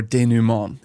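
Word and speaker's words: dénouement: There's the choral intro, the solo soliloquy, the totally wild cosmic chorus, the hard rock climax anguish section, and the tragic denouement, or denouement, if you want to pronounce it the proper French way.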